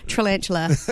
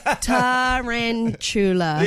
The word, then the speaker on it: tarantula: Tarantula. Tarantula. – Yeah, you just sort out your pronunciation, mate. – Yeah. – Or your emphasis. – Emphasis. – My emphasis on the wrong syllable.